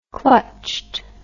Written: clutched

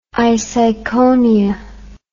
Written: iseikonia

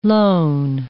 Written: loan

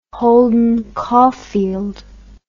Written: Holden Caulfield

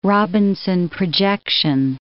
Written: Robinson projection

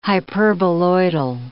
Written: hyperboloidal

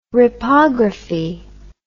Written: ripography

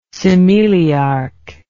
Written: cimeliarch

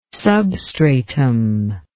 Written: substratum

